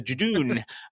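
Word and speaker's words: Judoon: And we also meet the Jadoon. I was going to pronounce that wrong. The didgeridoos. No, the Jadoon.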